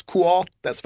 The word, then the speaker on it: Coates: Men ska ändå uttalas Coates för att så säger de då tydligen Uruguay.